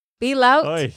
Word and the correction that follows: bail out: de nuevo. Bail out.